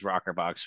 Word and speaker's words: rokrbox: So our core business is Rockerbox. We do internet lead conversion for real estate professionals all across the country. And it's spelled a little strange. It's R-O-K-R-B-O-X.